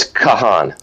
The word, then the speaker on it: Kahan: So it's Kahan. – Kahan. Got it. Kahan. – Yeah, actually, they made sure we spelled it K-A-H-A-W-N, so everybody pronounced it correctly, but it's K-A-H-A-N.